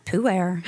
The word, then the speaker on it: pu-erh: Well, I kept mispronouncing it until my right-hand tea guru, Morgan, was like, no, it's pu-erh.